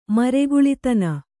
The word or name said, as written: ಮರಗುಲಿತನ